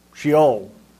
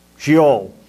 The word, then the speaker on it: Sheol: In the Old Testament, it's the Hebrew word Sheol. Sheol. S-H-E-O-L. Pronounced Sheol.